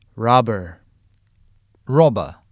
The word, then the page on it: robber: American English; British English